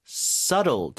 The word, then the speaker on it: subtle: subtle. 자, be는 묵음이죠? 다시 한번 읽어주세요.